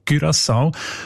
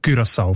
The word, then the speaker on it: Curaçao: Das sind eben die Aussprachendatenbank, die haben nämlich gerade letzten Dezember die Ausspracheempfehlung geändert von Curaçao auf Curaçao, weil sie beziehen sich dort auf eine Aussprachenduden und auf das digitale Wörterbuch von der deutschen Sprache, wo beide eben Curaçao vorschreiben. – Danke vielmals. Jetzt hast du mir den Namen von der Insel gesagt, wie man den ausspricht. Curaçao mit Ü in dem Fall.